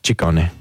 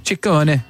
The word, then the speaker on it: Ciccone: Well, in Italian, it works the other way. So the double C's are hard C's and the singular C's are soft C's. – So Ciccione. – Ciccone. – Ciccone. Madonna Ciccone. God, why'd she drop that? That's good. It's Ciccone.